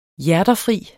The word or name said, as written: hjerterfri